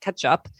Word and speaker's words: kecap: It's spelled K-E-C-A-P. That's the first name. That sounds exactly like ketchup. And then manis is how that is spelled how it sounds, which is M-A-N-I-S.